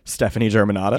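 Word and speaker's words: Stefani Germanotta: Stephanie Germanotta.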